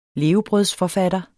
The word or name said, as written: levebrødsforfatter